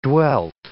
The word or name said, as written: dwelt